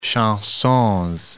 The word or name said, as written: chansons